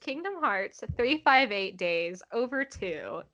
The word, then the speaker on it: Kingdom Hearts 358/2 Days: The title of this game is actually pronounced Kingdom Hearts 358 Days Over 2, and it was released in 2009 on the Nintendo DS.